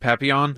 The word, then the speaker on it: Papillon: Papillon.